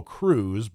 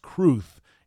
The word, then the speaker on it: Cruz: who everybody pronounces Bo Cruz, but C-R-U-Z is actually pronounced Cruth in Spain.